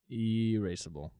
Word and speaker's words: irascible: Hold on, let me make sure I said that right before you start spelling it. – Erasable? – Yeah, I said it right. Erasable. Erasable. – Is it like E-R-A-C-E? B-L-E? – E-R-A-C-E? B-L-E? – No, S-S-S-S-S.